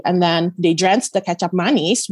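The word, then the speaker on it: kecap manis: It's spelled K-E-C-A-P. That's the first name. That sounds exactly like ketchup. And then manis is how that is spelled how it sounds, which is M-A-N-I-S.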